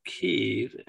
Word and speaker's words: Kyiv: I'm in what some people call Kyiv and others call Kiev. Stu, I like me some chicken Kiev, so I'm not changing the way I pronounce it. It's the way I've always said it, and everybody knows what the hell I mean. Even though I know that's the Russian way of saying it, and for that, I will probably be attacked as a Russian sympathizer.